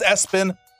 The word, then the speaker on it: ESPN: Now, the SIDs of the team, you know, whoever, they'll say, no, it's pronounced Espen, but it's spelled ESPN and then last name Lamprun.